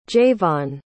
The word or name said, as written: jayvon